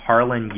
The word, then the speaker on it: Harlan: My name's Harlan Yu. First name is spelled H-A-R-L-A-N.